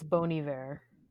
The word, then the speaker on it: Bon Iver: Say that is the most chaotic way I've ever heard that pronounced. – What, Cole House? – No, it's Bon Iver.